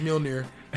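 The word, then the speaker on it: Mjölnir: I've always wanted to learn how to say them right ever since Mjolnir. – Thor's hammer. – Yeah, I know. Damn hammer. Mjolnir. I call it Mjolnir. – You saying it all wrong. All fucked up. – The axe. – Butchering it. – What's the axe? The axe is there. I call it Javnajorn. I can't. I know the J's are silent.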